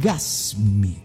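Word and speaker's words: GasMy: Se trata de la aplicación GasMe. Igual que Gas, se escribe Gas M-Y, GasMe.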